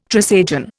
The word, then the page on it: Trisagion: Indian